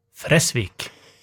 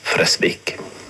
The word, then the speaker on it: Fresvik: Stedet, det uttales Fresvik. – Navnet uttales Fresvik.